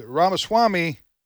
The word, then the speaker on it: Ramaswamy: And that doesn't appear to be going to happen anytime soon, especially between DeSantis and Nikki Haley, because Ramaswamy and, is that pronounced his name correctly? – Yes.